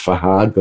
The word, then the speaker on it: Fahd: So it's Fahad, but it's spelled F-A-H-D.